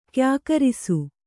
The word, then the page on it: ಕ್ಯಾಕರಿಸು: Kannada